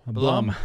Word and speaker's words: Blum: Blum. – Blum. – Blum. Yeah. – How do you spell it? – B-L-U-M.